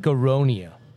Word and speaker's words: Aronia: your black aronia. You have that in here. Did I say it right? Aronia? – Yes.